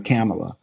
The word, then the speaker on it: Kamala: I just should just call her what I've been calling her, Kamala. That's easier. – No, I think the way you just now said it was right, Kamala.